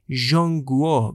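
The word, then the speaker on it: ژانگوا (中国): این حکومت چین که در زبان انگلیسی با املای QIN نوشته میشه، با کشور چین که در زبان چینی اگر درست و دقیق تلفظ کنم ژانگوا گفته میشه تفاوت داره.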